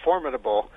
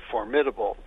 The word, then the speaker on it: formidable: I was looking in the American Heritage Dictionary, and it says that from the standpoint of accent, the word formidable is accented on the first syllable, not, as a lot of people say, formidable.